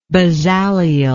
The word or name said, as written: Bezaleel